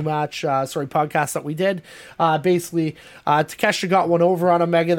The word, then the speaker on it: Takeshita: Let's start with Kenny Omega versus I said it wrong for 2 weeks in a row. I'm just going to say Takeshita.